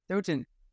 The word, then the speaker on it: Stoughton: Stoughton.